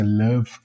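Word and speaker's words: LÖVE: É uma coisa que é alemã, tem origem alemã, né, se pronuncia Love, aquele O meio alemão que é um ambiente de, de, de jogos, tem é, gráfico, umas bibliotecas, tudo, tudo programado em Lua.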